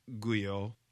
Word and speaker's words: Guyo: Guyo